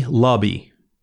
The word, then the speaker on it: Luhby: Okay, now this was written by Tammy Lubby, L-U-H-B-Y. How do you say that? Lubby. It's, hi, I'm Tammy Lubby. Lubby.